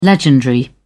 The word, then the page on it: legendary: British English